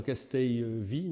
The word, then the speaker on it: Castelvi: En fait, alors c'est un professeur, Castelvi, je sais pas comment on dit, parce qu'il est espagnol, Castel, deux L, ça se dit comment? Alors Castelvi, non? Ouais. Ouais, comme ça s'écrit.